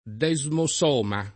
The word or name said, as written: desmosoma